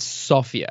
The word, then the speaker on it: Sofia: Am I pronouncing it correctly? – It's Sofia, actually. – Sofia. – But everybody says Sofia, yeah.